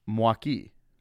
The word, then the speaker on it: Milwaukee: All of those different, I guess, pronunciations could always land us in the right area, but the way that it's supposed to be pronounced is Mwaki.